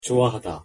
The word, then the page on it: 좋아하다: Korean